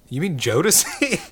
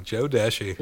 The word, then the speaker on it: Jodeci: I was like, and like, not really even hearing the conversation, I was just, you mean Jodeci? And he was like, yes, right? Okay. Like, I'm not crazy. He's saying the name wrong. Oh, Joe Deshi.